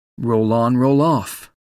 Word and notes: roll on roll off: American English

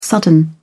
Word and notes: sudden: British English

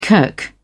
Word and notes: kirk: British English

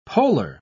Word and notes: poler: British English

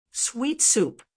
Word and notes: sweet soup: American English

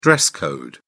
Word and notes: dress code: British English

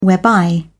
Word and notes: whereby: British English

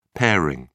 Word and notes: pairing: British English

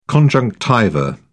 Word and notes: conjunctiva: British English

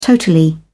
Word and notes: totally: British English

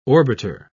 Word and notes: orbiter: British English

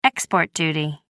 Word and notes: export duty: American English

